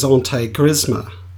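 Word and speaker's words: intégrisme: The French have actually come up with a word for what's going on in France, and it's the spelling is I-N-T-E-G-R-I-S-M-E, and the pronunciation is antigrisme.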